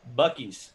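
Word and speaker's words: Buc-ee's: from Boosie's, he says. – Bucky's. – Or Bucky's, that's how you say it.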